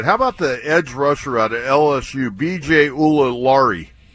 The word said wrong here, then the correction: Ojulari: Ojulari. You just wanted to try to pronounce that. – Yeah, Ojulari.